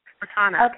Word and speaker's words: Cortana: And they have that thing called, is it Katana? Is that how you pronounce yes. – it? – Cortana. – Okay.